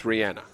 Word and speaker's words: Rihanna: See, I've been hammered already today because I called her Rhiannon, and now you're calling her Rihanna. So, how do you say her name? – It's Rihanna.